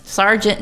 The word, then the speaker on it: sergeant: So I was picking on you because, you know, I never realized how we, sergeant and surgeon, the correct spelling is S-E-R. – Yes. – Right? – Yes. – Is that funny? Yeah. But it's spelled sar, it sounds.